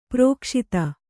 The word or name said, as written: ಪ್ರಕಾಶಿತ